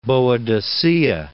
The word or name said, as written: Boadicea